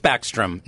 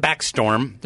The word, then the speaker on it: Backstrom: And by the way, it is Backstrom, not Backstorm. There's no storm involved.